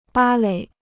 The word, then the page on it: 芭蕾: bā lěi